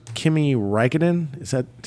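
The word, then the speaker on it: Kimi Räikkönen: You know, what I thought was very unique was one of the great F1 drivers, Kimi Raikkonen.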